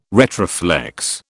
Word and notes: retroflex: British English

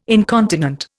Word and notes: incontinent: Indian

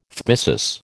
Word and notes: phthisis: American English

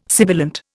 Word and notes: sibilant: Indian

